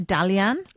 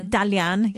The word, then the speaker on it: Dalian: But she was saying it's different brands from Hong Kong to Beijing to, she went to Dalian, Dalian province. – Dalian. – Yeah. Thank you, my pronunciation.